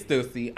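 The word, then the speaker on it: Stüssy: It's Stussy. I stay Stussy. Because I like the way Stussy sound, because it's like bussy and like pussy, but like Stussy works too. But it's Stussy, Stussy, Stussy.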